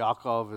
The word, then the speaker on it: Yaakov: You know, the author here, he doesn't stop and say, hey, dear reader, what Yaakov did, remember Yaakov is the Hebrew way to pronounce Jacob, Yaakov, what Yaakov did and did to his dad and his brother, that was bad in the eyes of the Lord.